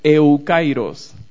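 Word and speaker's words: eukairos (εὔκαιρος): Esto tiene que ver con la palabra que encontramos que se llama, que se pronuncia eukairos. La palabra es una palabra compuesta por eu y kairos.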